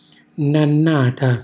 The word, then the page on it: nannata: Calabrese